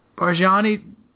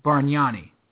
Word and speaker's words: Bargnani: Barjani tries to take off.